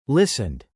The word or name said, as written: listened